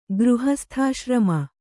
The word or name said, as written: ಗೃಹಸ್ಥಾಶ್ರಮ